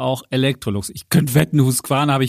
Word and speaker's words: Husqvarna: Also ich kann dich beruhigen, Holger, Husqvarna hast du vollkommen richtig ausgesprochen.